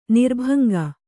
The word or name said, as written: ನಿರ್ಭಂಗ